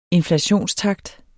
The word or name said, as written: inflationstakt